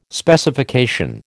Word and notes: Specification: American English

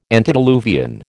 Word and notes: antediluvian: American English

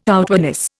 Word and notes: outwardness: Indian

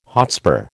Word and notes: hotspur: American English

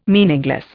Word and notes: meaningless: Indian

